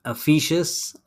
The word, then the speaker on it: Efes: Efes, I believe, is the way it's pronounced in Turkey, as well as the islands of Greece like Mykonos and Santorini.